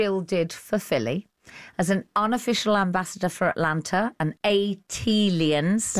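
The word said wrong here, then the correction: ATLiens: That is pronounced A-T-aliens. – Thank you. – Like Atlanta alien, A-T-alien. – A-T-alien, gotcha. And A-T-aliens, to quote Outkast.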